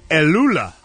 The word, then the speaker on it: Elula: Elula. Elula. – Excuse me? – Elula. Elula. – Spell, please. – E-L-U-L-A.